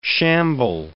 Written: shamble

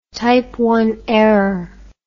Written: type I error

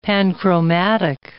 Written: panchromatic

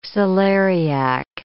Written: celeriac

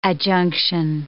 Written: adjunction

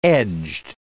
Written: edged

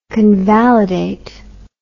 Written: convalidate

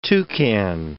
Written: toucan

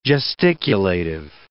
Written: gesticulative

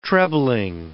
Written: trebling

